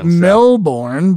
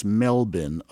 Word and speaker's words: Melbourne: I always pronounced it Melbourne, but when I was there, I was told, no, it's Melbourne.